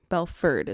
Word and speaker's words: Belford: that was Belford is how you say it.